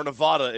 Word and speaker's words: Nevada: But my team on upset alert is Nevada, or Nevada if you prefer to pronounce it that way.